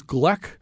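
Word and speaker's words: Glück: I think you say her name, Louise Gleck. It's G L U E C K.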